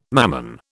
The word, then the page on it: Mammon: British English